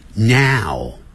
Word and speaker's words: now: 所以这个时候啊，他在上海， she is in Shanghai now，句尾的这个 now， N-O-W 念是 now Now，先念啊，那个双元音啊，再念个 ow ow，不要念成 ow， ow 的时候嘴巴就没有全开，你会念成 now， now 就不对了。